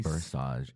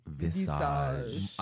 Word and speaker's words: Visage: They had judges, Michelle Versace, Mike. – You keep Ruiz. – Saying Versage. It's Visage.